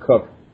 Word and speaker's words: Coke: One of the guys who was the champion of the common law in England, his name was Edward Coke, C-O-K-E, or they pronounced it Cook.